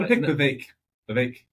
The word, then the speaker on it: Vivek: Vivek. Comes with cake.